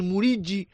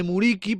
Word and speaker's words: Muriqi: ya que aquí todo el mundo quiere decir Muriqi en lugar de Muriqi para pronunciarlo en albanés.